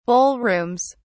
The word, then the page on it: ballrooms: British English